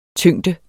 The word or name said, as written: tyngde